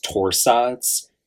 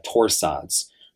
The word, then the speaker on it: torsades: But my point is that potassium channel blockers and sodium channel blockers are known to cause a sinister arrhythmia referred to as torsades, torsades. So there's a French pronunciation with that.